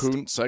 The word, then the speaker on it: Kuntz: Say it the right way. You know how that's pronounced. – Kuntz, I guess, would be proper. – Kuntz is the correct pronunciation.